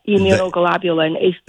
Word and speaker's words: immunoglobulin: Yep, immunoglobulin.